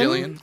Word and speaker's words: Gillian: Pronounced with a G. – Pronounced Gillian. Gillian. If you say Gillian, she gets super upset.